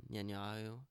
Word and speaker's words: Netanyahu: Obviously Netanyahu? – Netanyahu. – Am I pronouncing it right? – Netanyahu. – Yeah.